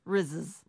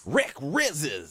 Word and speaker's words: Rizzs: Rizzes? R-I-Z-Z-S. How would you say it? – R-I-Z-Z-S? – Yeah. – Rizzes. – Would you say Rizzes? – Rizzes. – Rick Rizzes.